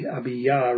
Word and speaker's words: Abijah: It's interesting that the author of this second book of Chronicles refers to Rehoboam's son as with the name Abiyah, and it looks A-B-I-J-A-H, and I think it's accurately pronounced that with a Y, Abiyah rather than Abijah.